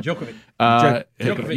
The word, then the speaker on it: Djokovic: Here is Karine Jean-Pierre, again, this goes back to the Novak, is it Djokovic? Did I say that right? – Djokovic.